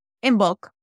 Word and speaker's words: Imbolc: Do you say Imbolc or Imolc? How do you pronounce that? – Imbolc.